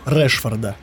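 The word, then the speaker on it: Rashford: Во-первых, чумовая форма Решфорда или Рашфорда, как правильно? – Решфорда. – Решфорда. Да, произносит Решфорд.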